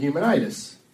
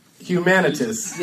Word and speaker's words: Humanitis: I surprise myself when I pronounce it right, because I want to say Humanitas. – Yeah, that's your normal pronunciation. – Like the prize, the Humanitas Prize.